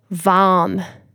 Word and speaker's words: vam: One last tip and technique you could do as a daily practice is to silently or aloud sing the syllable VAM, V-A-M, VAM, 6 times.